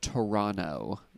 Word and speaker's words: Toronto: They want you to know it's pronounced Toronto. You don't pronounce the second T.